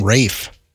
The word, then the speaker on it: Ralph: The main actress from Queen's Gambit and Ralph Fiennes, which is spelled Ralph, which... How can we call him Rafe? – I have no clue.